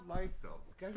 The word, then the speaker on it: Liefeld: Liefeld? – It's Rob Liefeld.